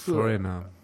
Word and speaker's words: Foreigner: Foreigner.